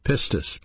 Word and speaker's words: pistis: That word is a noun pronounced pistis, P-I-S-T-I-S.